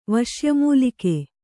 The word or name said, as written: ವಶ್ಯಮೂಲಿಕೆ